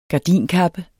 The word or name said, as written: gardinkappe